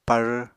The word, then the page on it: par: Apatani